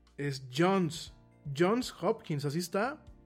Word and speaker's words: Johns Hopkins: No Johnson's, es Johns Hopkins. Johns, eh, no de John, sino de Johns Hopkins. Así lo estaba pronunciando, ¿no? Lo pronuncié mal. Es Jones, Jones, no, no, está bien pronunciado. Jones Hopkins.